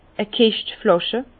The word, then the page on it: e Kischt Flàsche: Alsatian; Bas-Rhin; Herrlisheim